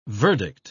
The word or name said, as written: verdict